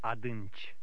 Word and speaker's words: Adânci: But when this combination ends a word, the I is silent. Try to read number nine. – Adânci.